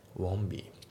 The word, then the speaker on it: won't: Want のところの T が抜けますね。 Won't be、ね、 Won't be じゃなくて Won't be ですね。